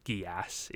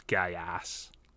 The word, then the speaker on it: Geass: I have to point out, maybe you have been poisoned by the meme, but it is pronounced Geass. It is not Geass.